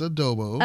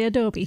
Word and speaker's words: adobo: It's adobo. – I was going to say adobe.